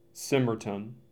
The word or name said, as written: Symerton